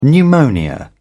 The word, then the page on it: pneumonia: British English